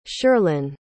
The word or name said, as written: shirlyn